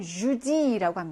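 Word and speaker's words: jeudi: 그다음 2번은 judy라고 합니다. 요게 정답이 되겠죠. 주 이렇게 발음이 나죠.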